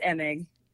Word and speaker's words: Emig: It's Emig.